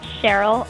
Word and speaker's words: Sheril: Well, you can find me on Twitter at Cheryl _, but it's spelled S-H-E-R-I-L, and then that little underscore after my name.